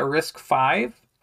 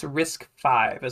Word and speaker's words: RISC-V: I think we're supposed to say that RISC-V. I think it's RISC-V is how you say that.